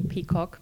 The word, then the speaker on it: PCAWG: So, yeah, we pronounce the abbreviation PCOG, which makes it a bit quicker.